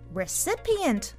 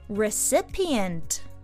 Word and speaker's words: recipient: recipient を言ってみましょう Repeat. Recipient. E と I の発音の違い、要注意。